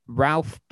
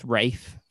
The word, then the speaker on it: Ralph: So we'll talk about The Dig, the Netflix film The Dig, starring Ralph, but actually pronounced Rafe for some reason, yes.